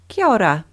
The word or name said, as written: kia ora